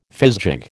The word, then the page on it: fizgig: American English